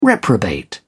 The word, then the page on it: reprobate: British English